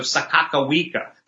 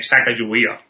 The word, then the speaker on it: Sakakawea: And they took advantage of Sakakawea. That's how you, I guess you pronounce her name, Sacagawea. Sakakawea. I was corrected by somebody who knows the correct.